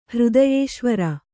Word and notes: ಹೃದಯೇಶ್ವರಿ: hřdayēśvari

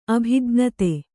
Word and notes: ಅಭಿಜ್ಞತೆ: Kannada

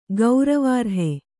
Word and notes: ಗೌರವಾರ್ಹೆ: gauravārhe

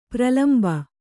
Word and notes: ಪ್ರಳಂಬ: Kannada

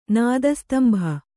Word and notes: ನಾದಸ್ತಂಭ: kannada

